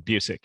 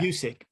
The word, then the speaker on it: Busiek: how do you say his last name? – You got right the second time, Busiek. Yeah, Busiek.